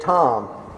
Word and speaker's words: Thom: And another really good model of metasystems is there's a theorist called René Thom, and his name is spelled T-H-O-M, René Thom, and he came up with a thing called catastrophe theory.